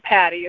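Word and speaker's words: Patti: My name, Patty, is spelled with an I.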